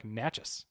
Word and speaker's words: Natchez: It was that's how I always said it, but he says like I think it's just like Natchez. It's or uh, Natchez? it's like it's it's like two syllables, but it looks like it should be four.